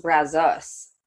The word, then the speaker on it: Brazos: Yeah, so apparently it's pronounced Brazos, which I just learned.